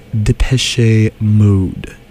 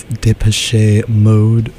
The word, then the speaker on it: Depeche Mode: early 1980s and all throughout the 90s, even up until recently in our very own 2000s, was Depeche Mode.